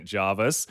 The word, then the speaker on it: Jervis: I said that they might be in Jervis/Jarvis Bay, said that I wasn't sure of the pronunciation. Your auntie texted you moments after that saying, can confirm it's Jarvis, 100% Jarvis.